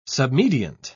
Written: submediant

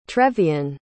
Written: trevian

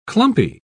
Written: clumpy

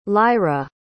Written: lyra

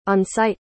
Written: on-site

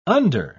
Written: under

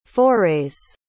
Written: forays

